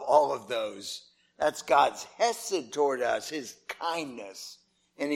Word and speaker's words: chesed: You could spell it like this, C-H-E-S-E-D. It's kind of a hard sound at the beginning, chesed.